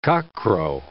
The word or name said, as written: cockcrow